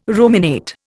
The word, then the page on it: ruminate: Indian